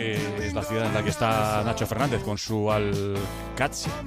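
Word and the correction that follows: Al-Qadsiah: Bueno, se suele decir Al-Qadsiya, pero también hay gente que lo dice Al-Qadisiya. Es que es difícil, ¿eh? No es fácil, pero Al-Qadsiya es lo más fácil.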